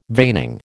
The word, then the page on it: veining: American English